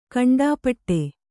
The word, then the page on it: ಕಂಠಪಾಠ: kaṇṭhapāṭha